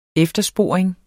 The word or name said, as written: eftersporing